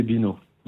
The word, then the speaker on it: Bino: Mon nom d'artiste, c'est Gemma, qui s'écrit X-H-E-M-A. – D'accord. – Et moi, c'est Bino, donc B-I-N-O. – Donc Gemma et Bino, X-H-E-M-A et Bino, B-I-N-O.